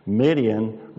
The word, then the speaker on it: Midian: Midian rhymes with Gideon, right?